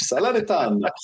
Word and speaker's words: Salernitana: Salernitana. I knew I was going to butcher that. Take it on Inter Milan. This game is Friday, 2:45 Eastern time here in the States. Who do you like here? – Loving the pronunciation, coach. Salernitana.